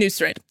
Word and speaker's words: Nusret: I'm sorry, it's pronounced the same way as his name, Nusret. Well, it's written all fancy. I thought maybe it was going to be different.